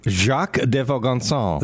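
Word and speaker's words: Jacques de Vaucanson: In 1737, some guy, his name is, pronounce that one, Jacques de Vizcazzi. – Jacques de Vaugansan.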